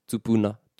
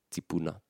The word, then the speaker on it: tupuna: So iwi more on the West Coast of the North Island, te ka Māui, keen on the u appearing in kupu. So tupu, tupuna, tupuna. And then towards the east coast of Te Kau Māui, you hear the E being pronounced more. Tipu, tipuna, tipuna.